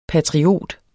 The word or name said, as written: patriot